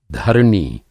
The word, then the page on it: धरनी: Hindi